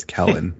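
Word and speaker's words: Kellen Moore: One thing I will say is, Keelan Moore, this is your time to fucking shine. So. – Well, he would be mad that you got his name wrong. It's Kellen, but.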